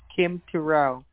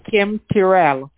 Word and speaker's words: Terrell: You're speaking with Kim Terrell. Did you receive a notification call about a prize you're supposed to receive? – I did. What did you say your name was again? – Kim Terrell. – Can you spell that for me, please? – S-K-I-M-T-E-R-R-E-L-L.